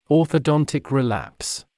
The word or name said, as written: orthodontic relapse